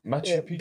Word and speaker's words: Machu Picchu: Machu Picchu. – Pikachu? – I'm joking. – Machu Picchu.